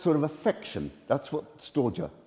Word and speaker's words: storge: The first one is called storge. That's perhaps a Greek scholar would say it doesn't pronounce like that, but S-T-O-R-G-E, right?